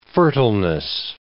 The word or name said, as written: fertileness